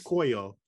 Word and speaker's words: Tlacoyo: Okay, so for our listeners, Tlacoyo. – This snack is from Mexico, Tlacoyo. And it's spelled T-L-A-C-O-Y-O.